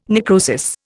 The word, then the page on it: necrosis: Indian